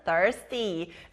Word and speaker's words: thirsty: TH. TH 소리 나는 thirsty. 자, 그럼 목마른 thirsty 다시 발음해 볼게요.